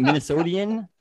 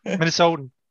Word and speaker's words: Minnesotan: Is that how you say it? Minnesotian? Minnesotan? – Minnesotan.